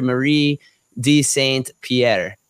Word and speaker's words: Marie de Saint Pierre: She is the great, great, great niece of Sister Marie de Saint Pierre.